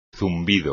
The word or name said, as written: zumbido